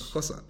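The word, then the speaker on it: Xhosa: Kosa.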